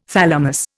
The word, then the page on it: thalamus: Indian